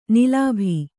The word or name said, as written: ನಿಲಾಭಿ